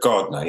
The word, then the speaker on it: Gardiner: It's only Scottish people who can pronounce it correctly, I noticed. It's really a Scottish name, but Gardiner, yes.